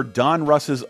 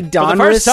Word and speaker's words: Donruss: It's Don Russ. It's oh, one man. Word, D-O-N-R-U-S-S, all one it. Word. You said Don Russ, like it was two names, one having a last name.